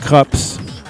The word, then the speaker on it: Krupps: And it's pronounced Krups, named after the German dynasty that assisted the Nazis with arms during World War II.